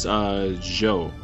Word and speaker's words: Jeux: I think it's Joe. Joe Video. J-E-U-X. Joe.